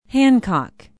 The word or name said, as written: hancock